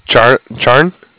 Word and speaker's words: Charan: C-H-A-R-A-N, Charan. – Charan? – Charan, yeah. – Charan. C-H-A-R-A-N? – Right.